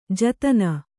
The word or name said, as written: ಜತ್ತನ